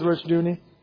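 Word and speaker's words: Rushdoony: Oh, Rushdooney instead of Rushdani? Okay, I'll go with that. Rushdooney. It's R-O-U-S-A-S is his name. First name, and his last name is Rushdooney. I'll go with Rushdooney.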